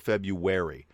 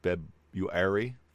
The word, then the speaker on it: February: It's one of those weird things where I don't think most people in the United States pronounce that right. We go February, and it's not. There's no w sound in there, right? It's just February.